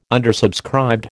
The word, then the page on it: undersubscribed: American English